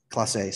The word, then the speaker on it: Clase: We love Clase. – Class A, sorry.